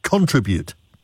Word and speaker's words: contribute: I probably would say contribute, but some people say contribute. – Everybody does, and I checked it on the smartphone, and sure enough, the accent is on, actually they put it as treat. Contribute.